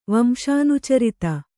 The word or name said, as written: ವಂಶಾನುಚರಿತ